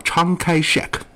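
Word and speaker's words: Chiang Kai-shek: 这常凯申啊，是咱有的翻译，人家因为这个外国的这个书啊，这个蒋介石叫常开 shek 啊，就可能是粤语发音啊，就后来就翻译成了常凯申。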